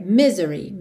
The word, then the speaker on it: misery: Não é misery, é misery, me, e não me. Ok? Misery. Normalmente a sílaba tônica é mesmo a primeira,